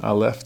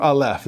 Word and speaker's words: Aleph: For Aleph, this is the A sound, although in reality it's a silent letter in the Hebrew alphabet that just takes on the sound of whatever vowel it's next to.